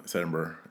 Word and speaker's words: Edinburgh: Do you keep throwing the G in there? – Yeah, like burger. – Yeah, but it's Edinburgh. It's almost like you put the R early in the syllable. – Edinburgh. You're cold, like Edinburgh. – Yeah. – Okay. Yeah, Burr. Yeah, I'll take that. – Like Aaron Burr.